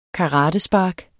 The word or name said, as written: karatespark